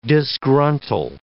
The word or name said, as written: disgruntle